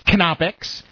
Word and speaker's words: Knoppix: Yes, the K is not silent. It's pronounced Knoppix.